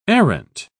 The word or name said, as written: arrant